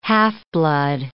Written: half-blood

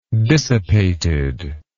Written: dissipated

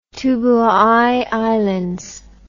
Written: Tubuai Islands

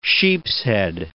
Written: sheepshead

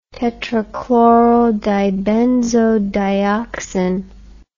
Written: tetrachlorodibenzodioxin

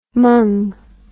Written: mung

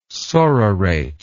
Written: sororate

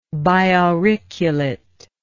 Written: biauriculate

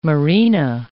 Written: marina

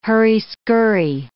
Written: hurry-skurry

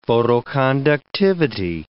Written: photoconductivity

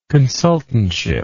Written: consultantship

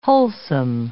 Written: wholesome

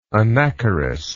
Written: anacharis

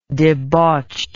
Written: debauched

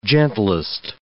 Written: gentlest

